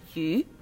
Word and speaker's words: 余: In Chinese, fish has the same pronunciation as the Chinese word yu, which means surplus or extra.